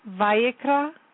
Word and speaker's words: Vayikra: Vayikra, he called, Leviticus.